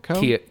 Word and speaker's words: Keough: Keough.